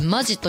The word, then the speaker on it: 本気: 私はその中の清田店に行ってきたんですけれども、あのね、ここのソフトクリームが本気と書いてマジと読むんですけど、そのマジソフト、ミルクとチーズと、ピスタチオとミックス、ミルクとピスタチオのミックスの 4 種類ございまして、これがねでかいんですよ。